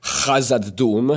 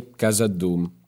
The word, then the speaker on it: Khazad-dûm: By the way, in Italian, you shouldn't say Hazad Doom, as it should be in the Elvish pronunciation, because in Italian, I don't know why, they don't really use those sounds. – Interesting. – So you should just say Hazad Doom.